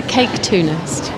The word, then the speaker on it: caketoonist: So, the cake cartoonist. – It's the caketoonist.